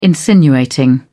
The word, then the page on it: insinuating: British English